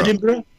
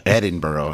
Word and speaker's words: Edinburgh: but Edinburgh? – Something can't be fixed. – Edinburgh? – Sorry? – Edinburgh. – Edinburgh? – Yeah, Edinburgh, right? – Edinburgh.